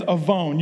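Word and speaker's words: avon (עָוֹן): It's spelled with a W. You pronounce that W like a V. I couldn't write A-V-O-N because I didn't want a line of pink cars out here next week saying that I'm calling their company sin. If you don't get that, ask your parents. It's pronounced avon.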